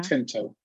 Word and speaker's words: Tinto: Tinto. Vincent Tinto.